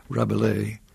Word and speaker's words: Rabelais: Rabelais is what I've always called him. Maybe the S is not supposed to be pronounced. – Probably not. – Rabelais.